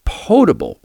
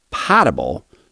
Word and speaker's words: potable: Second, the word potable, meaning safe to drink, is pronounced potable, long O, and not potable, short A. Think about the show Jeopardy, in which there is a category of potent potables. Potent and potable have the same first syllable.